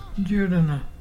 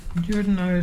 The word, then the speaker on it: juruna: Our local Mulgana word today is Jordana. Jordana is pelican, and to spell it is J-U-R-U-N-A.